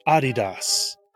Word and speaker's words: Adidas: To create his company's name, Dassler took his three-lettered nickname, Adi, spelled A-D-I, and abbreviated his last name, Dassler, to simply Das, D-A-S, and thus, Adidas. Yes, pronounced like that, became the second and new brand of the three stripes.